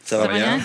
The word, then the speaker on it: Sauvignon: Sauvignon.